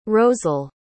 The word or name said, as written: rosel